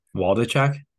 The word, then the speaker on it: Waldichuk: I want you to try to pronounce his last name. – Waldichuk? – There you go.